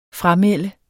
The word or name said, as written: framelde